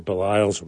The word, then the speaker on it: Baliles: Baliles, with a name that many people had difficulty pronouncing, reminding folks that Baliles rhymes with smiles, is actually going into his third retirement at the Miller Center up at UVA.